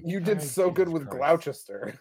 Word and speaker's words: Gloucester: It's Gloucester, yeah.